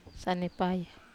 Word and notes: sanepaya: Wamesa

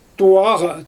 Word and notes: tuwaɣa: Xyzyl

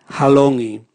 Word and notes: halongi: Raga

